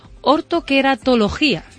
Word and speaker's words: Ortoqueratología: Enrique, vamos a hablar, a ver si lo digo bien, ¿eh? Ortoqueratología. – Efectivamente.